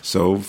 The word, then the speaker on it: salve: yeah, I think the L is silent. Salve.